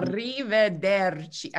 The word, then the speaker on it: arrivederci: Which is um, extremely difficult for Americans to say. yeah, it's a bit tricky. – I still of, can't say it right. Ar- arrivederci. Okay, it's too many R's. – It's good. It's good. You did it well.